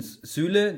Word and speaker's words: Süle: Sulu heißt der, nicht Sule. Also, Zulu, jetzt ich bin, der heißt Sulu. Der heißt. – Zulu. – Jetzt bin ich durcheinander gekommen zwischen Süle, den habe ich falsch mit Sule ausgesprochen.